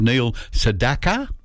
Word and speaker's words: Neil Sedaka: Up next we have Neil Sedaka, is how he's properly pronounced his name, with Calendar Girl.